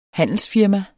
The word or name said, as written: handelsfirma